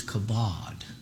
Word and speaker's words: kavod: The Hebrew word is kavod. Some places it's pronounced kavod, K-A-V-O-D or K-A-B-O-D.